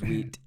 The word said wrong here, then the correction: Witt: with Marcos Witt.